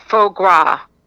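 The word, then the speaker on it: foie gras: And I think it's pronounced faux gras for the liver, the duck liver.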